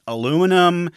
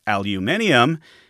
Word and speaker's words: aluminium: First he liked alumium, then aluminum. The Americans picked up on that spelling and pronunciation, but the British scientists argued it should be aluminium, and they stuck to their guns.